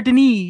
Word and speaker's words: Denis: Is that how you pronounce her last name? – Denis. – Claire Denis? Claire Denis, yes, French. Real French.